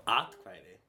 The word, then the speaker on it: atkvæði: Trump fekk of mörg atkvæði. – Atkvæði? – Atkvæði.